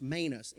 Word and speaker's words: manus: It's pronounced manus, M-A-N-U-S, and it means this.